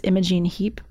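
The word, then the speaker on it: Imogen Heap: Imogene Heap, is that how you pronounce her name? – Oh, yes.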